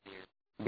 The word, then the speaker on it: bier: Okay, what is haram is to accompany the buyer. How do you pronounce it? Buyer or beer? – Beer. – Beer? Same thing as the drinks.